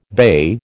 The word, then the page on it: bey: American English